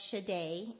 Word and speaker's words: Shaddai: Shaddai is the Hebrew word, and it's pronounced Shaddai in Hebrew.